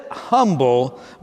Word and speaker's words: Humble: You spell it humble, but in Texas they say humble.